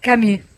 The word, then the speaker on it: Camus: Camus. – Pensá en una I y decí una U. O pensá en una U y decí una I y ahí te sale.